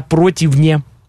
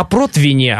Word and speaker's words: противне: А если такая погода, как в Москве, постоянно пасмурно и все такое, можно на противне. Это все сушить. – В духовке, да? – Да, в духовке. В духовке. Просто все говорят на противне, а правильно говорить против, на противне.